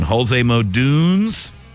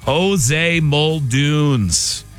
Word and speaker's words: Jose Muldoon's: Jose Muldoon's. I blew the pronunciation there. Let me try that again. Jose Muldoon's.